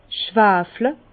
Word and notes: schwafle: Bas-Rhin; Herrlisheim

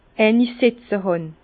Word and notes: eini setze hàn: Bas-Rhin; Herrlisheim

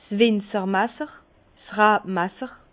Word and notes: 's Winzermasser: Bas-Rhin; Herrlisheim

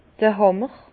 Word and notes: d'r Hàmmer: Bas-Rhin; Herrlisheim

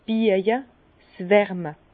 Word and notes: 's Biäje, Wärme: Bas-Rhin; Herrlisheim

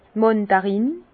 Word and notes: d' Màndarin: Bas Rhin; Herrlisheim

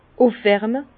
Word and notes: ufwärme: Bas-Rhin; Herrlisheim

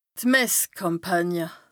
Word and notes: Messkàmpànie: Bas Rhin

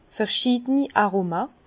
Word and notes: verschiideni Aroma: Bas-Rhin; Herrlisheim

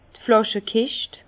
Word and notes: d' Flàschekischt: Alsatian; Bas-Rhin; Herrlisheim

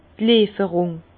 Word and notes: d' Lieferung: Alsatian; Bas-Rhin; Herrlisheim

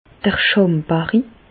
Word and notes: d'r Schàmbarri: Alsatian; Bas-Rhin; Reichshoffen

